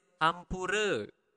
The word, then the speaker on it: ampura: Sorry is ampura. Ampura. Again, you need to pronounce the R clearly. Ampura.